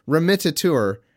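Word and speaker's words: remittitur: Okay, but you skip a full four letters if you pronounce it that way. Three letters. Remittiture. Just for the record, let the record state that the word is remittiture, and Andrew is saying remitter or something like that.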